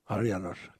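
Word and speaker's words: Háry János: Tonight, he will be conducting the Harry Janosch Suite. Did I say that right? – Harry Janosch. – Harry Janosch. – Kodai, yes.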